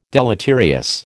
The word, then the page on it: deleterious: American English